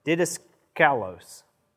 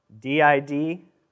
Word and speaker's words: didaskalos: The word teacher here is, let's see how we pronounce this, didaskalos, didaskalos. And it means instructor or teacher in the Greek.